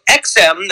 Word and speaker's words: EXIM: So EXIM, that's how it's pronounced, E-X-I-M, EXIM, is an independent executive branch agency with a mission of supporting American jobs by facilitating the export of U.S.